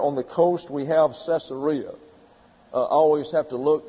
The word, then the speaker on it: Caesarea: But it's pronounced Caesarea.